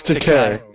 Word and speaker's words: Takei: That's Takei. – Is it Takei? – Takei, yeah. – Rhymes with O-K.